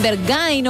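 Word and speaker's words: Berghain: La milésima intacha es la de Rosalía, Barcay. – Es Bergain, o como cada uno lo quiera pronunciar, pero efectivamente es esta canción súper conocida, lo decíamos, actual y que suena mucho.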